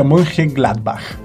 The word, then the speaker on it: Mönchengladbach: Borussia Mönchengladbach.